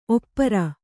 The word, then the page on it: ಒಪ್ಪಾರು: oppāru